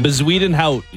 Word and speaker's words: Bezuidenhout: Do you know how to say his name? – Bezuidenho. – Oh, that's not even close. That's not even close. – Christian Bezuidenho. – No. – Bezuidenho. – Try again. – Bezuidenhout.